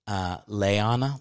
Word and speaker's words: Liana: I won't say her last name, but she's like, her name is spelled Liana. And then she's like, it's actually pronounced Leana, like Leana Hammock.